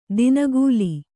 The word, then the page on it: ದಿನಗೂಲಿ: dinagūli